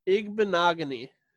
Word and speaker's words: Igbinoghene: Noah Igbinoghene. Igbinoghene. – Nailed it. – Igbinoghene.